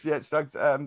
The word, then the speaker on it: Suggs: Good pickup with Shugs, however you pronounce his name. – Suggs. – Suggs.